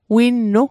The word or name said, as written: winnu